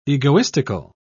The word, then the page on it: egoistical: British English